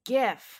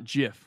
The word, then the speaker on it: GIF: People knew something should be pronounced GIF and not JIF, which is the peanut butter.